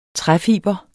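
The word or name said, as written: Træfibre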